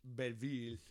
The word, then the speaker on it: Belleville: Belleville.